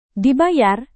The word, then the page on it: dibayar: indonesian